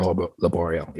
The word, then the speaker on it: Laboriel: Laboriel.